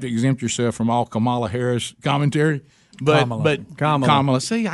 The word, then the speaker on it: Kamala: That's her name. It's Kamala. Say it right.